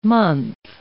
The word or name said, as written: month